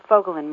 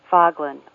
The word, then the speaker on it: Fogelin: My last name, Fogelin, means little bird in Swedish, and it must be tricky because lots of people say it wrong. They say Foglin or Fogelin.